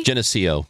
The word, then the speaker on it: Geneseo: Is it Geneseo, Illinois? – Geneseo.